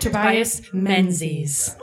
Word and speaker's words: Tobias Menzies: Tobias Menzies.